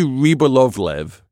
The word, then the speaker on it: Rybolovlev: It's Dmitry Ryobov. Wait, what? Okay, Dmitry Rybolovlev, and he sold the painting basically because he got ripped off.